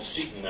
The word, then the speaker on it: Seton: The commentaries were called Seton Notes. S-E-T-O-N is my last name, and Seton Notes is spelled backwards as Seton, so Seton Notes was a palindrome.